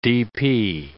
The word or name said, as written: DP